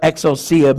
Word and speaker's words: exousia: The Greek word of authority is exousia. It's spelled E-X-O-U-S-I-A.